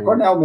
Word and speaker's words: Cornell: Em primeiro lugar, se pronuncia Cornell? Ou... – É Cornell mesmo. É Cornell. Uh,